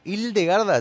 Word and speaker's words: Hildegarda: ¿Hildegarda, lo digo bien? – Hildegarda von Bingen, lo decís muy bien.